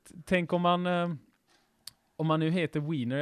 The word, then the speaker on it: Weiner: Han uttalas Weiner förresten, för det är i.